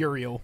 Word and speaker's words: Uriel: Gabrielle and Ur- Ural. How do you say his name? Uriel. Uriel.